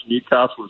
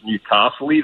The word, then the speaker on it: Newcastle: Earlier, someone pronounced Newcastle as Newcastle-y.